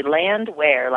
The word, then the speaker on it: Landwehr: Land where? Like, where is the land? – Oh, see, now I'll remember it.